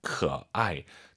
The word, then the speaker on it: 可爱: 삼성 다음에 사성이면 반삼성입니다. 可爱.